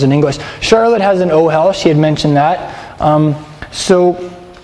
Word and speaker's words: ohel: In Hebrew, a tent is an ohel, O-H-E-L. Everybody say ohel. – Ohel. – Yes. Emphasize the last syllable in that word.